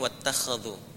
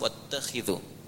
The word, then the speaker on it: واتخذوا: Kalau di riwayat Warash atau Qalun itu dibacanya wattakhadhu. – Riwayat Hafs dari Asim wattakhidhu.